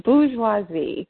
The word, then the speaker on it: bourgeoisie: The bourgeoisie.